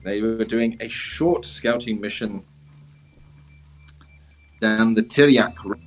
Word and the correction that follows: Tiryaki: No, that's not how you pronounce it. It's Tiryaki. Tiryaki.